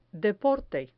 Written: deporte

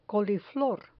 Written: coliflor

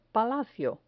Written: palacio

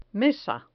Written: mesa

